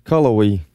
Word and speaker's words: Cullowhee: Just spell it out, Chris. I'll pronounce it for you. I got that kind of thing. – C-U-L-L-O-W-H-E-E. – You're going to have to go a little slower than that. Go ahead. What now? – Cullowhee. – C-U-L-L-O-W-H-E-E. – That sounds right, Cullowhee.